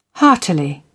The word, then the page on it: heartily: British English